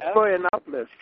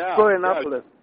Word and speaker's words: Florianópolis: Florianópolis. Florianópolis.